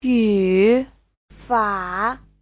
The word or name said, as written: yu3fa3